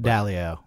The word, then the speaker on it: Dalio: I think it's Dalio. – Dalio.